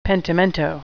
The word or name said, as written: pentimento